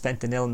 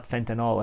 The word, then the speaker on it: fentanyl: I believe it is pronounced fentanyl, not fentanyl, I think it's written N-Y-L, fentanyl produced in China in a quantity enough to kill off the entire American population.